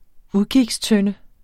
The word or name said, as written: udkigstønde